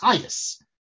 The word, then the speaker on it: Chajes: So he wrote it in German, C-H-A-J-E-S, Chayus. I guess Chayus is Chayus, Chayut, I guess.